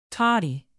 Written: toddy

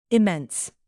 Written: immense

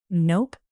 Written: nope

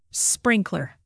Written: sprinkler

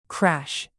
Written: crash

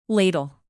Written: ladle

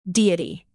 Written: deity

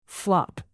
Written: flop

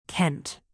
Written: kent

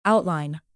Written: outline

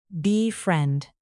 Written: befriend

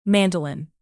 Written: mandolin

